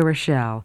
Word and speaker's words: Rochelle: First, my name, Rita Rochelle. Rita is spelled R-I-T-A. Rochelle is R-O-C-H-E-L-L-E.